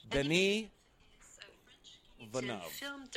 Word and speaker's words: Denis Villeneuve: Today we're going to learn how to pronounce the name Denis Villeneuve in French. – Denis Villeneuve.